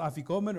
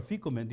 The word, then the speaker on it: Afikoman: The Afikoman is pronounced Afikoman or Afikoman, depending on how you want to pronounce it.